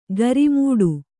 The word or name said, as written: ಗರಿಮೂಡು